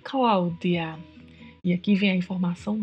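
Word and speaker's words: Claudia: Cláudia também tem uma particularidade de pronúncia, que é o DI. No espanhol, não existe a pronúncia de, e sim di. Portanto, qualquer palavra em espanhol que você encontrar com DI, pronuncie sempre di. Então, o nome é Cláudia.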